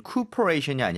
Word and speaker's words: cooperation: COO가 있는데요. 앞에 CO 그리고 나서 OP 이렇게 연결되는 거 발음이 아예 달라요. 그래서 맞아요. – cooperation이 아니라 cooperation이 맞죠? – Cooperation.